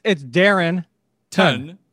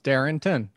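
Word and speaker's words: Darrynton: It's Darren Ton. – Yeah, but is that like, is it like Demaryius Thomas where there's like an extra letter that you don't pronounce in the name kind of thing? – No, it's Darrington. Darrington. – All right. Well, I do pronounce the N in there.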